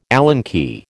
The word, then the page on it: Allen key: American English